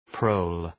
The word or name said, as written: prole